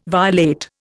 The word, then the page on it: violate: Indian